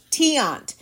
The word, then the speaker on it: Tiant: Tiant.